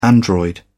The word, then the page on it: android: British English